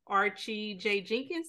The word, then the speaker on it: Archie J. Jenkins: Today we will be talking to Mr. Archie J. Jenkins.